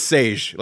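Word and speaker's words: Šejić: I heard it was Sage. Like he actually put a pronunciation on his Twitter. – Stefan Sage. Yeah, Sajic. – But he leaves the IC off. He says it's how you like Sean Connery would pronounce his name.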